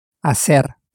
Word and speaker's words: hacer: Hacer. – Hacer. – To turn this into you do, you simply get rid of the R as we have done before. So again, what is to do? – Hacer. – Hacer. – Spelt H-A-C-E-R.